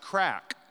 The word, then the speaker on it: craic: But in Ireland, they have a word for fun or a good time, and it's spelled C-R-A-I-C, and it's pronounced crack.